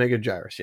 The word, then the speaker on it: Megaguirus: M- Megaguirus, yes. Oh, is that how you say and it? We just got an exposition dump of I think so what Mega Megaguirus